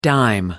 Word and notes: dime: American English

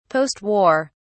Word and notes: post war: British English

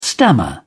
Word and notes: stammer: British English

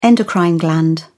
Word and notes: endocrine gland: British English

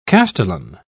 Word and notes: castellan: British English

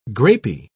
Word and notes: grapey: British English

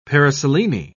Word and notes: paraselene: British English

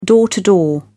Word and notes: door to door: British English

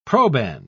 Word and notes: proband: British English

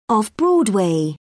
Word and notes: off broadway: British English

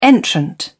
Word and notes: entrant: British English

